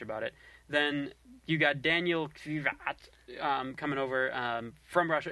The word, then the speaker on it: Daniil: I guess it's Daniel or similar to Dan. Daniil. And then KVY. – Daniil Kiyawai. – Yes, that's it right there.